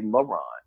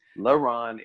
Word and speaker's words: LeRon: it's easier to say Ron than to say LeRon, you know, because Ron is just, it's just one syllable, it's just Ron, but LeRon is two syllables.